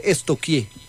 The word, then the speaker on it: stalkear: Porque no se dice nunca stalkeé, se dice estoquié.